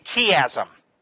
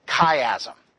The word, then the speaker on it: chiasm: This is what we call a chiasm, or some people pronounce it chiasm.